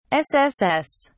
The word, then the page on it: sss: British English